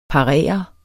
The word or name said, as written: parere